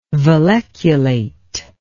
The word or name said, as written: valleculate